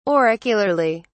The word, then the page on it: auricularly: British English